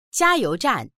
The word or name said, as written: jia1you2zhan4